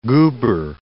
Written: goober

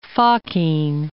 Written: Fokine

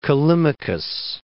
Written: Callimachus

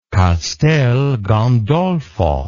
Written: castel gandolfo

